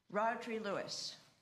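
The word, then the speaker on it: Rhodri Lewis: Rodri Lewis, did I say it right? – You did.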